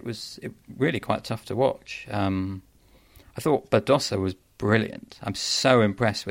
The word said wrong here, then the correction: Badosa: Hello, Spanish speakers pronunciation. – Badosa. – All right, I'm going to correct myself going forward then.